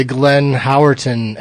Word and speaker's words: Glenn Howerton: I listened to the Glenn Howerton episode.